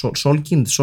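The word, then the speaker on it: Salkind: Αλλά ουσιαστικά τι έχει οδηγήσει σε αυτή την ανάγκη των Solkinds, νομίζω καλά το προφέρω, το, το Solkind, Solkind, ναι, ναι.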